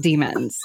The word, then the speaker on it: daemons: we're talking demons, not daemons, like the way it's spelled in the book. – So it is pronounced demons, you dork.